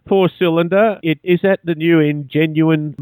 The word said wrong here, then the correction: Ingenium: model? – Yes, if that's how it's pronounced, the new Ingenium.